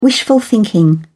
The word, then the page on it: wishful thinking: British English